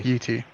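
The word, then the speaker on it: Yi Ti: Is that how you pronounce it? Okay. – Yeah, Yi-T.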